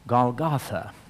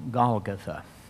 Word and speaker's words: Golgotha: And then verse 17, and he, bearing his cross, went forth to a place called the place of the skull, which is called in the Hebrew Golgotha, or some people pronounce it Golgotha.